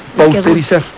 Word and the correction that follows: pasteurizar: No, proviene del científico que desarrolló ese proceso, que es Luis Pasteur, que se escribe Pasteur. Entonces el término correcto es pasteurizar. – Entonces no es pausterizar.